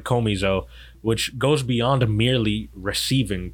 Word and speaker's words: komizo: Well, you said komizo is like more like an accent on the O. Komizo. Yeah. – Komizo. I'm not sure, but it's okay. K-O-M-I-Z-O.